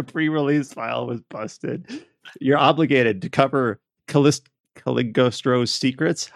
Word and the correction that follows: Cagliostro: How do you pronounce that? – Cagliostro. – I can't speak Italian. – Like silent.